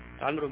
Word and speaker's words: عمرو: عمرو الحجر كله مقام طبعا إبراهيم. الواو لا تنطق.